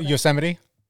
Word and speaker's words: Yosemite: And the story I tell in chapter 5 of my book is about this little girl who her dad said in Yosemite Park. Is that how I pronounce it? I'm Canadian. – Yosemite. – Who's Yosemite. Not impressed with that. No, Yosemite Park. – I'm also in Canada, but more about that later. – Okay. So Yosemite.